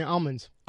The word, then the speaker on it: almonds: California almonds. Almonds. That's correct. It's almonds. – Uh, he said an L. – He no, said he it. didn't say an L.